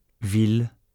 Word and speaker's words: ville: There are three exceptions where i deux l is pronounced il and not y: mille, ville, tranquille.